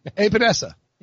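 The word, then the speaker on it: Epenesa: Right. Sound it out. – Epinesa. – Yeah, that's good.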